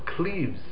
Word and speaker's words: Cleves: There was in the town of Cleves, that's how you pronounce it in English, in French it's pronounced somewhat differently.